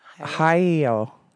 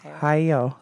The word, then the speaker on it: Hayao: But I know how to say his name. It's Miyazaki is his last name. – Oh, Hiro Miyazaki. – It's Hayao. Hayao.